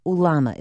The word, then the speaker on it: ulama: This game of ulama, is that how you say it? – That's right.